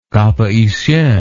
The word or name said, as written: Cap Haitien